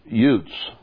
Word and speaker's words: Uz: Uz would be pronounced Utz in Hebrew.